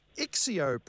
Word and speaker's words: Excio: Now, have I pronounced Ixio properly? E-X-C-I-O.